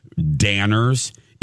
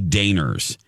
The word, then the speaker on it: Danners: She pronounced the store called Danners. It was really Daners. And I didn't know that until I started having friends. And everyone's like, that's not how you say it.